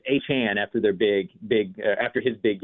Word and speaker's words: Achane: I mean, you were the first one that I heard that from, but we've been calling him Achane since he was in high school, but apparently that's not right. – Yes, Achane.